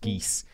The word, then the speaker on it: Guise: As I like that this Guise place is pronounced geese.